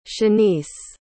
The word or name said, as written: shanese